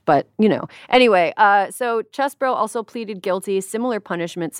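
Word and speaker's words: Chesebro: The other one was Kenneth Chesbro, which everybody keeps wanting to say Cheesebro because he's from Wisconsin, and it looks like it's pronounced Cheesebro, but it's Chesbro.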